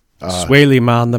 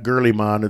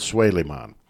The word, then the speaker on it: Suleiman: Suleiman the Magnificent. – Suleiman the Magnificent, yes. It's not Gurliman, it's Suleiman.